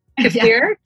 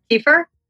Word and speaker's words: kefir: Kefir? Kefir? – Yeah, both are correct and both are used.